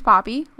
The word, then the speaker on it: Poppe: So my name is Paige Poppe, like the flower. Many, many people don't know how to say it, so there you go.